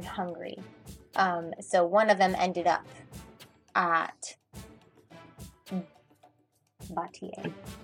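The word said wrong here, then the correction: Baratie: You're so close. Baratier. – I'm missing a syllable.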